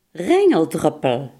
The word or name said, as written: regendruppel